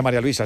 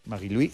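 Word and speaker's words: Marie-Louise: No se dice María Luisa, se dice en su... – Sí. – Marie-Louis Eta.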